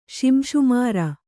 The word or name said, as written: ಶಿಂಶುಮಾರ